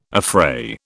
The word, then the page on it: affray: British English